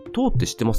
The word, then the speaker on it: toe: で、次が 2 曲目が、これはあの、結構インストゥルメンタルのバンドなんですけど、トーっていう、 TOE って書いてトーって読む